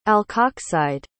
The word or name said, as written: alkoxide